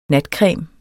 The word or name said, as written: natcreme